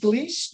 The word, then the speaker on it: Gleis: It's R-A-D. H-I-A. And my last name is Gleis, G-L-E-I-S.